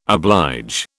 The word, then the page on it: oblige: British English